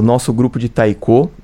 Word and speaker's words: taiko: Bom, vamos agora falar sobre o grupo de taiko. Essa é a pronúncia. – Acertei. – Taiko. – Isso.